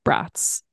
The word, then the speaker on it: Braatz: It's a great moment to remind listeners that your last name is Braatz. – Braatz. Yeah. Like pronounced like multiple German sausages.